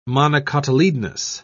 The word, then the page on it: monocotyledonous: British English